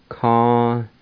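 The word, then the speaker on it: kaniquisa: A-G-O-S-G-A. A-G-O-S-G-A. A-G-O-S-G-A. Number two, rat. Ca-ni-gu-sa. Ca-ni-gu-sa. Ca-ni-gu-sa. And on that Q-U-I, all you do is pronounce the Q sound and the U-I is silent. Let me pronounce it again. Ca-ni-gu-sa.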